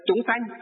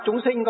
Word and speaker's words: chúng sanh: Chúng sanh. Thì trong miền Nam họ đọc chúng sinh có nghĩa là chúng sanh.